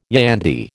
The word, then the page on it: yandy: American English